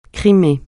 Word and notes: Crimée: francais

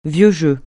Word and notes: vieux jeu: francais

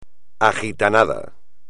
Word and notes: agitanado: espagnol

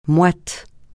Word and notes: moite: francais

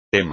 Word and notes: tema: espagnol

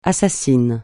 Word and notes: assassin: francais

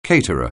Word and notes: caterer: anglais

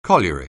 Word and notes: colliery: anglais

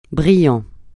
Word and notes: brillant: francais

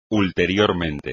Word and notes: ulteriormente: espagnol

